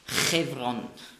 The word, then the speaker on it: Hebron: Und Hebron spricht man aus Chevron.